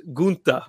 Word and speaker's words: Gunther: So the German pronunciation is Gunther.